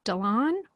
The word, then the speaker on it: Delon: I would almost say it's like a noir of sorts, in which a hitman, Jeff Costello, played by Alan Delon, which mm-hmm. I believe that's how you say his name. – That sounds about right.